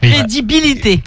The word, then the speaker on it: crédibilité: Financièrement parlant, je n'avais aussi aucun intérêt, j'ai perdu des partenariats, j'ai perdu ma crédité. – Ta crédité ? – Ouais. – Non, alors tu as oublié une syllabe, tu as oublié le lit. – Crédibilité. – Débilité, c'est comme débilité.